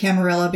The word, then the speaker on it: Camarilla: The Camarilla and the anarch, I pronounce it Camarilla because I think it's supposed to be an Italian word, not a Spanish one.